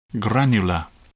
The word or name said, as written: granular